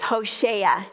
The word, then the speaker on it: Hoshea: Hosea, pronounced in Hebrew, is Hoshea.